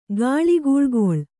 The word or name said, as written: ಗಾಳಿಗೂಳ್ಗೊಳ್